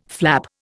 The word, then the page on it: flap: Indian